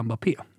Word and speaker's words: Mbappé: Mbappé, uppenbarligen, så finns säkert olika sätt att uh, uttala honom på, men uh, globalt så säger vi Mbappé.